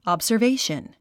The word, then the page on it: observation: ɑ`bzə r véiʃən; アブザベィション